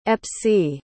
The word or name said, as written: epsie